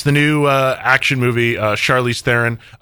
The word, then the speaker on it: Theron: Just so it's clear, it is pronounced Theron, which I learned this morning. Like, for my entire life, it's been Theron. Like, I've always pronounced it Theron. And it is Theron.